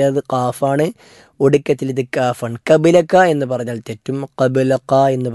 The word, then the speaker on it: قبلك: മൂന്ന് അക്ഷരങ്ങളാണ് ഈ ഭാഗത്ത് ഉച്ചാരണത്തിൽ നമ്മൾ ശ്രദ്ധിക്കേണ്ടത്. ഒന്ന്, അല്ലാഹുമ്മ അൻചൽ ഔവലു ഫലൈസ ഖബ്ലക, അവിടെ ഖാഫ് ഖബ്ലക എന്ന ഒറ്റ വാക്കിൽ തന്നെ അറബിയിലുള്ള രണ്ട് കാവും വരുന്നുണ്ട്. മലയാളത്തിൽ ക, അതുപോലെ ഇംഗ്ലീഷിൽ കെ എന്ന അക്ഷരമുള്ളത് ഖബ്ലക എന്ന അവസാനത്തെ കാഫാണ്.